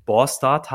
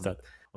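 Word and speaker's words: Båstad: Ich hatte ja erwähnt, dass äh, wir unsere Unterkunft äh, in Borstad hatten. Also so wird das ausgesprochen. Ausgeschrieben wird das, äh, also wenn man das liest, äh, heißt das irgendwie, also B-A-S-T-A-D, also Bastard sozusagen. also wie das Schimpfwort quasi. Ja. Und ich habe es immer so ausgesprochen. Bastard.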